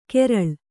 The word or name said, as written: ಕೆರಳ್